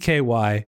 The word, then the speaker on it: Brezoczky: It's a tricky one, Brezoczky.